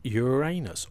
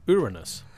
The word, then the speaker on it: Uranus: This week we're looking at Uranus or Uranus. – Indeed we are, yes. Uranus or Uranus, depending on your preferred pronunciation.